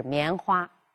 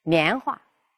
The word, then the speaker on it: 棉花: 我们还应该结合语体来谈，比如“ 棉花” 一这个词，它在这个新闻语体里边，“ 花”，哎，尽量不要读作轻声，就是“ 棉花”。但是在对话和主持人节目中呢，这个“ 花” 呢就可以读成轻声，“ 棉花”，这样呢就可以使语言更口语化。